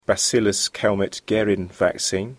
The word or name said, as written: bacillus Calmette-Guérin vaccine